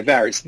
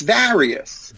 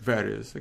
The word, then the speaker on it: various: V-A-R-I-O-U-S is the same pronunciation as Darius. Most of my clients do not pronounce the word Varius correctly. They say Varius. Like Varus Computers, Varus Companies. It's Varius. – Varius, okay.